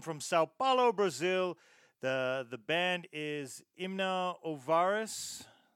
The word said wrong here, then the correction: Inomináveis: Did I even say that right? Imna Ov- sorry. Nameless is what it translates to. – Inomináveis. – Inominovai. In, so let's do it again. Inomináveis. Inomináveis.